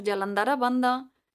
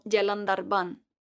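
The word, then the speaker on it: Jalandhara Bandha: Así que vamos a conocer todas estas cerraduras, comenzando por Yalandara Banda, la cerradura de cuello o garganta. En realidad, la A final no se pronuncia, así que podrás escucharlo más habitualmente como Yalandarban.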